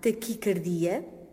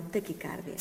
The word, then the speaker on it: taquicardia: Portanto, a palavra é grave, taquicardia, com a tónica na sílaba di, e pronuncia-se assim, deste modo, devido à origem da palavra. Portanto, cardia e não cárdia. Taquicardia e não taquicárdia.